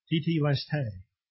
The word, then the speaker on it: tetelestai: And that word in Greek is tetelestai, and it's spelled T-E-T-E-L-E-S-T-A-I.